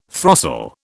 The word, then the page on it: throstle: British English